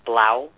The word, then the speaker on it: Blau: Blau. Am I saying that correctly? Blau syndrome? – Correct. That's exactly right.